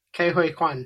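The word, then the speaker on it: Ke Huy Quan: And it's, how do you say his name? Ki Kwai Kwan? – Kei Hui Kwan. – Kei Hui Kwan. Apologies.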